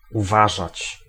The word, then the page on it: surveiller: France; [syʁ.ve.je]